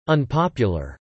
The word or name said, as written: unpopular